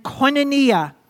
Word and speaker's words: koinonia: And koinonia. Everyone say koinonia again. Look at a neighbor and say koinonia. Let's spell it. Go ahead. K-O-I-N-O-N-I-A. Koinonia.